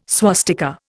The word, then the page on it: swastika: Indian